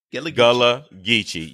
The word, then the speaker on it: Gullah Geechee: Am I pronouncing Gullah Geechee? – Gullah Geechee. Yes.